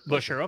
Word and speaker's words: Buescher: It, it's just Busher. – Busher.